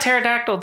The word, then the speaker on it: pterodactyl: Well, the weird thing about pterodactyl, too, meaning pelican, was it's because of the silent P.